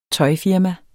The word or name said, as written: tøjfirma